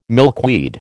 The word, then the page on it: milkweed: American English